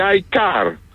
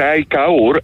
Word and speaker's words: iCar: Είναι δεδομένο ότι έρχεται η iCar. Κανονικά προφέρεται iCar, αλλά καταλαβαίνετε ότι δεν είναι και πολύ εύθυχο. Οπότε προτιμάμε να το λέμε iCar, γιατί το iCar παραπέμπει αλλού, καταλαβαίνετε.